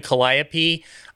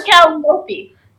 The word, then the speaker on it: calliope: Gracie's gonna do an Arabian dance with George playing the calliope. I'm totally down. – Or calliope. – What, calliope? Yeah. – There's two pronunciations of calliope and calliope.